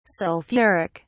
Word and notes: sulphuric: British English

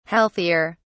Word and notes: healthier: British English

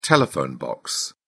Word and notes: telephone box: British English

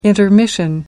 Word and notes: intermission: American English

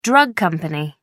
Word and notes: drug company: American English

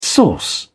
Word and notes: source: British English

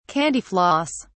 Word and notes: candy floss: British English

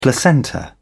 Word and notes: placenta: British English

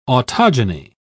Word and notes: autogeny: British English

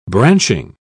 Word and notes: branching: British English

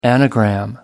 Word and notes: anagram: American English

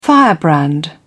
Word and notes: firebrand: British English

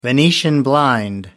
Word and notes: venetian blind: American English

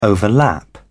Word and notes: overlap: British English

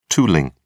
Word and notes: tooling: British English

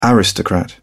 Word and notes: aristocrat: British English

